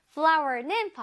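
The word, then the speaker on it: flour: 발음은 꽃 flower랑 같은데 스펠링이 다르죠? 그치만 발음은 같아요. flower는 바로 밀가루라는 뜻이에요.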